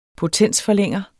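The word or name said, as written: POTENSFORLÆNGER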